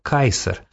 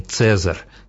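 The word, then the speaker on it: Caesar: Из надписей вроде этих понятно, что императорское имя, позже ставшее титулом, читалось в древности скорее как «Кайсар», а не «Цезарь», как его стали произносить в средние века, и тем более не «Чезаре», как в современном итальянском.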